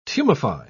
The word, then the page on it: tumefy: British English